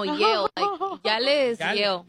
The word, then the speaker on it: Yale: No se dice, se dice Yale. – Es Yale.